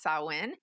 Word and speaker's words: Samhain: Halloween has sort of taken that over, but essentially it comes from Samhain, which looks like the spelling of Samhain, but is actually pronounced Samhain.